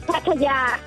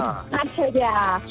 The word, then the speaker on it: Pattaya: The correct pronunciation is Pattaya. Hang on, can she say Pattaya. That again? – Pattaya. – So there's a long Pattaya. Ya at the end.